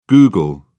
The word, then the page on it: google: British English